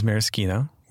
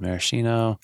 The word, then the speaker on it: maraschino: So it's one of those things that's kind of funny, especially with the pronunciation, because I think most people think of it as maraschino. I feel like in the cocktail world, I hear maraschino.